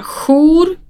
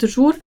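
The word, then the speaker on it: jour: А, поэтому можно, а, как я уже сказала, там вечер, выходной день, когда поликлиника не работает, а, есть дежурная поликлиника, она называется ХУР, пишется как ЖУР, многие называют ЖУР, как в слове «хуналист», «журналист» по-шведски «хур» — дежурство.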